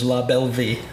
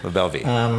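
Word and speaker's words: La Belle Vie: The proper French pronunciation is LaBelleVie. – LaBelleVie.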